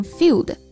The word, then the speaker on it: feud: 嗯，这个 feud 大家注意了啊，字母 e u 在这里发 u 这个音，所以它读成 feud。